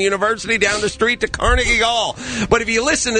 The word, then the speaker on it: Carnegie: If you notice, they start messing with the pronunciation of words. Like, it's Carnegie Hall. It's always been Carnegie Hall.